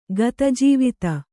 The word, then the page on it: ಗತಜೀವಿತ: kannada